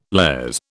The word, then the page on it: lares: British English